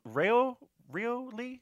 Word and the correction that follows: Ryu Lee: Ryu, I think it's Ryu. – Ryu Lee, okay.